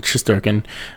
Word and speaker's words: Shesterkin: And when you see it Shesterkin, you want to say it phonetically, like Shesterk. But now he's with the signing Shesterkin, so I guess we're going to go with Shesterkin or Shesterkin for now. – Yeah, so we're going with Shesterkin.